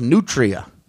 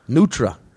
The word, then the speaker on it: nutria: Like if you read it, it's spelled and actually pronounced Nutria. Like a Nutria rat. – Wow. – But in New Orleans, they say Nutra.